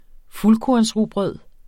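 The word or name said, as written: fuldkornsrugbrød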